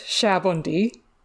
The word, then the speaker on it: Sabaody: Because, okay, so in Japanese, it's pronounced Sabaody, and the Japanese word Sabon means soap, so that's why it's called that, because of all the soap bubbles, kind of.